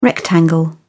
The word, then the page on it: rectangle: British English